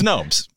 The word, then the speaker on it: Snopes: It's Snoops. – You know the, okay. No, it's Snopes. I'm just joking. – In other words, you don't know. It's 50-50. – No, it's Snopes.